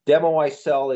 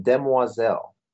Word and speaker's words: demoiselle: I live in a country where the second language is French, so I don't want to sound too effete here, but Demo I Sell is true. That is the pun reference, but the actual French pronunciation, demoiselle.